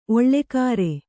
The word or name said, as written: ಓಲೆಕಾರ